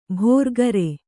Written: ಭೋರ್ಗರೆ

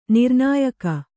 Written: ನಿರ್ನಾಯಕ

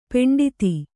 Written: ಪಂಡಿತೆ

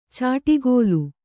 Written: ಚಾಟಿಗೋಲು

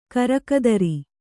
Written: ಕರಕದರಿ

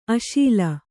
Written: ಅಶೀಲ

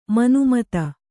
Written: ಮನುಮತ

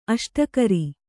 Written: ಅಷ್ಟಕರಿ

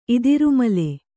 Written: ಇದಿರುಮಲೆ

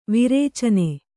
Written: ವಿರೇಚನೆ

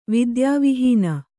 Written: ವಿದ್ಯಾವಿಹೀನ

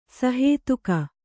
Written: ಸಹೇತುಕ

